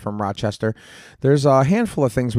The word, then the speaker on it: Rochester: They say it like smoother, faster. Rochester. Rochester.